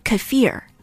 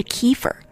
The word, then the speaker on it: kefir: 在美国，我们听到的大多数的发音是 kefir，可是专家说了，这样的发音是不正确的，正确的发音应该是 kefir，把重音放到 f i r 上面，kefir。